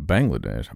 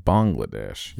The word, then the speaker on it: Bangladesh: See, not Bangladesh, Bangladesh. – Yeah, you know. – Yeah, did a little research. – He's been practicing all morning. – Bangla. A lot of mangoes in Bangladesh, right? – Yeah.